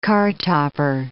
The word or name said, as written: cartopper